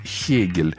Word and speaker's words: Гегель: Кстати, надо сказать, что немцы произносят его как «хёте», вот, как и Гейне у них. Хейне, а Гегель у них Хегель.